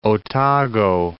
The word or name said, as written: Otago Harbour